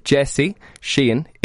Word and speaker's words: Sheehan: So J-E-S-S-E. – Yes. – It's not Jesse Sheehan, it's Jesse Sheehan. Still haven't worked out how to say that in English. Jesse Sheehan, S-H-E-E-H-A-N. – S-H-E-E-H-A-N.